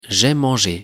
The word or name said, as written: jai mange